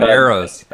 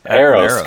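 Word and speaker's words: Aeros: He took a little batting practice with the Akron Aarons, I think, or Eons. – Akron Arrows. – Arrows, okay. Yeah.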